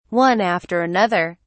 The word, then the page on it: one after another: British English